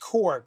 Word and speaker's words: Corps: Mongol dreams of galactic domination, while the Green Lantern Corps, for anyone out there who says Green Lantern Corpse, it's pronounced Core.